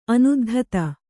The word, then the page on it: ಅನುದ್ಧತ: anuddhata